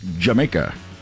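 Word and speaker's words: D'yer Mak'er: I know it's spelled like Dyer Maker, but it's pronounced Jamaica.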